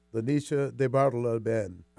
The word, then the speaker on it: Lanisha DeBartolo-Ben: Our next guest is the distinguished, illustrious executive director of the Northwest African American Museum, Lanisha DeBartolo-Ben. Did I say it right, Lanisha? – You said it perfectly.